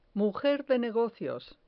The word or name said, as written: mujer de negocios